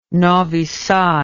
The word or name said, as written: novi sad